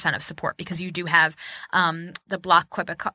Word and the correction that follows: Québécois: Oh, God, I just butchered that. The Québécois. I can't do French. – Québécois. – Ah, there we go.